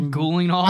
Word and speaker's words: Gyllenhaal: Jake Gyllenhaal is in this. – Gyllenhaal? Jake Gyllenhaal's evil brother, Jake Gyllenhaal. – I think that's how you actually are supposed to pronounce it.